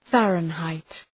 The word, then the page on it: Fahrenheit: ‘færən,haıt